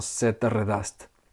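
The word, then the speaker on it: Þetta reddast: pero que se puede traducir algo así como la pronunciación como Sættaredast.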